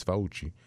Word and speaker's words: Fauci: And there are, and as you heard Brian say, Dr. Fauci, if you pronounce it in Italian, it's Fauci,